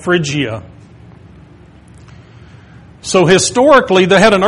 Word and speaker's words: Phrygia: This area geographically is called Phrygia, I believe is the way you pronounce that. Michael might be able to correct some of these later on. But I believe that's called Phrygia.